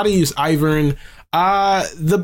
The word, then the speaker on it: Ivern: You ever, it's Ivern.